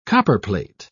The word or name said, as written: copperplate